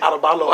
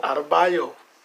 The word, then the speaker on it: Adebayo: Adebayo. I cannot say his name, man. – Bam Adebayo. – Adebayo.